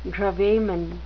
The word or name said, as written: gravamen